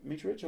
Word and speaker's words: Mitrovic: I love, is it Mitrovic up front? Mitrovic, I said that properly.